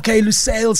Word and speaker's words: Lusail: So I'm chatting to the venue logistics manager and site logistics coordinator for Lusail. I hope I'm saying it right, Lusail Stadium. How's it going? – Good evening. Hi to the listeners. And it's called Lusail Stadium. – Okay. Lusail Stadium.